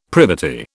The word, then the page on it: privity: British English